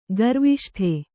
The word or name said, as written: ಗರ್ವಿಷ್ಠೆ